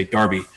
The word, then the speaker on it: Derby: Because the way we, D-E-R-B-Y, it, you know, for American English, that would be Derby. But you guys still say Darby.